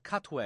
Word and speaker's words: Katwe: On Sunday the 15th, because of Mira Nair speaking at IU Cinema, her 2016 film Queen of Katwe.